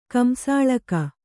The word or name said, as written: ಕಂಸಾಳಕ